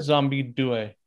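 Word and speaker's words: Zombi 2: There's no E at the end, Z-O-M-B-I, Zombie 2. But then over here, it got released as just Zombie. – Zombie, spelled with the, Zombie Due. That's what they would say in Italy.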